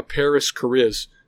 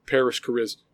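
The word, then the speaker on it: Parris Chariz: Your CHH song of the episode, the song Grace by Paris Cariz. Let me go ahead and spell that for you. P-A-R-R-I-S space C-H-A-R-I-Z, Paris Cariz.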